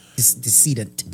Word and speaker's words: decedent: Did I say that right? Descendant? – Decedent. – Decedent. Okay.